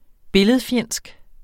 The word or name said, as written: billedfjendsk